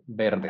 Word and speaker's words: verdes: Yeah, so you don't say the V doesn't exist in Spanish. It's verdes, okay. Not verdes.